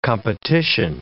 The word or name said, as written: competition